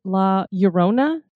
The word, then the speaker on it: La Llorona: La Llorona? – That's right.